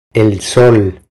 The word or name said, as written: El sol